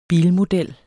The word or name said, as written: bilmodel